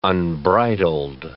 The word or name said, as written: unbridled